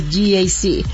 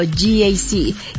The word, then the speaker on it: GAC: Se escribe GAC, pero se pronuncia GAC. Repítelo, GAC.